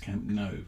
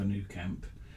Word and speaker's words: Camp Nou: But then he got on his soapbox a little bit and was like, no, it's Camp Nou. And you should know that, because all of the locals say it like that. The reality is, people say Camp Nou, Camp Nou, never New Camp.